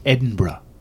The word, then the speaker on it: Edinburgh: It's pronounced Edinburgh. Is it really? It is. – Edinburgh? – Mm hmm. Like that? No, no, yeah, well, yes, but nothing to do with with bra, And when I saw this article, I, you know, I was like, it kind of serves Google right a little bit.